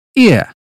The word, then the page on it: ear: British English